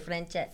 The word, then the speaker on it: francese: How do you say francese? – Francese.